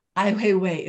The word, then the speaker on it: Ai Weiwei: Ai Weiwei is a really important Chinese dissident artist.